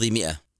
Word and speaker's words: مئة: 900 لها صورتان في الكتابة، والصورتان صحيحتان، المشهورة بالألف في لفظ مئة، والأصح على حسب القاعدة هو حذف الألف، فهي تكتب ولا تنطق وتحذف حتى لا يخطئ البعض بنطق الألف.